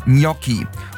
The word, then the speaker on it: Gnocchi: Gnocchi ungefähr Gnocchi.